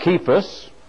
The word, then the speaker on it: Cephas: He was buried and rose again the third day, according to the Scriptures, verse 5, and that he was seen of Cephas, that's the way that is pronounced, not C, the little tilde or whatever it is on the bottom of the C gives it the hard K sound.